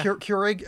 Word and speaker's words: Keurig: So then we swapped out with just getting like Keurig. Is that how you say it? – Yeah. – Keurig.